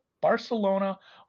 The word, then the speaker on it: Barcelona: I think it's actually a soft C. – Barcelona under 3.5 minus 105.